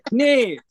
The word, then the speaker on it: Knef: Knef. – Hildegard Knef, the old German actor. Yeah, Knef.